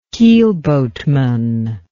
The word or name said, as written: keelboatman